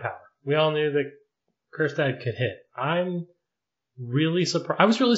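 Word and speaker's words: Kjerstad: Heston Kerstad, Silent J, as it were.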